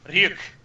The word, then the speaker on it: Ryuk: Ryuk? – Ryuk. Yes, yeah. You said it right.